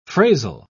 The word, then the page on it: phrasal: British English